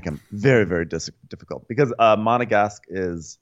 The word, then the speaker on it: Monegasque: Monegasque.